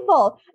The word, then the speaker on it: poi ball: What is a poi ball? Am I saying that correctly? – Oh, poi ball. Yeah.